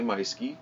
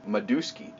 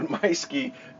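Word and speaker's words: Meuschke: That is spelled M-E-U-S-C-H-K-E. I know, it looks like Myski or Myoski, or I've heard tons of, all through high school, I was Maduski. I don't have a D in my name either.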